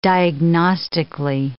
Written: diagnostically

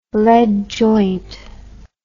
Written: lead joint

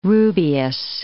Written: rubious

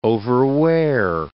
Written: overwear

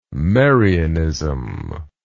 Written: Marianism

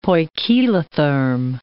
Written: poikilotherm

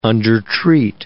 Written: undertreat

